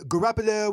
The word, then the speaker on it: Garoppolo: Garoppolo. – Garoppolo, what rhymes with Garoppolo? How about Key Lago?